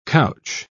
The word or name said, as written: couch